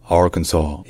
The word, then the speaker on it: Arkansas: L'État du Kansas, ça se dit Kansas, Kansas, alors que l'État de l'Arkansas, ça se dit Arkansas. Arkansas.